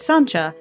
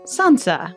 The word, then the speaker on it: Sancia: a girl named Sancha, although seeing her name spelled out, S-A-N-C-I-A, you might be forgiven for mispronouncing it Sansa.